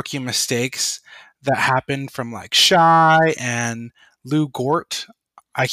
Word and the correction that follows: Dort: I think it's Lou Dort with a D. – Lou Dort. Yes, Dort. Excuse me.